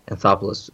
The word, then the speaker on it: Anthopoulos: Anthopoulos.